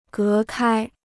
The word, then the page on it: 隔开: gé kāi